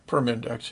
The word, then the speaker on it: Permindex: Permindex, am I saying it right now? – Yeah, Permindex, yes.